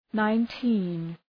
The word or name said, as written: nineteen